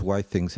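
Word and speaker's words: Withings: Ja bym, wiesz co, ale są takie firmy, na przykład ci od wagi, czyli WeThings, czy też WhyThings, jak ostatnio ponoć słyszałem, że to się wymawia, mają coś, co się nazywa, już mówię, ich produkt, Wireless Blood Pressure Monitor.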